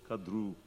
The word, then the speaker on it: Kadru: That should be a long U, Kadru.